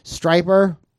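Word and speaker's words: Stryper: Striper rhymes with hyper.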